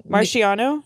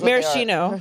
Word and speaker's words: Maraschino: Marciano? – Wait, how do you say it? – Okay, it's okay. Whatever. Don't be sounding this out. I'm like, Marciano. Marciano. – Everybody knows. – Maraschino.